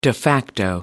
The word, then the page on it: de facto: American English